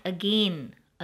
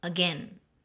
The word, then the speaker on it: again: Mark the pronunciation of the word again. It's not again, again.